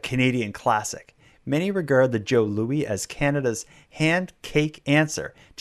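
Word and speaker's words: Jos Louis: The Joe Louis name has also caused a bit of a misunderstanding. The pronunciation of J-O-S as Joe, because it's spelled J-O-S, but it's called Joe Louis. The pronunciation of J-O-S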